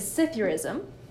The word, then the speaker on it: Psithurism: My word is Scythiorism. – And nailed it. – I know. I looked up a pronunciation video earlier. So it's spelled Psithurism.